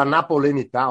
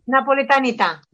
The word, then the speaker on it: napoletanità: Diciamo che questa cosa della napolenità, ormai ho coniato un neologismo. – Napoletanità.